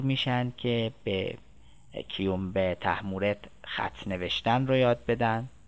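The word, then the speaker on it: طهمورث: تحمورس رو داریم که البته دکتر خالقی اصرار داره که با سه نقطه نوشته بشه، یعنی تحمورت اینطوری تلفظ بشه که معروف میشه تحمورت دیوبند.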